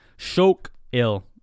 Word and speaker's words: Shokill: Shokill is how you say gray.